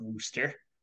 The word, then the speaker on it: Worcester: Wooster? – It's spelled Worcester, but according to like the release Nebraska sent out, it's pronounced Wooster.